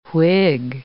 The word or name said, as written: Whig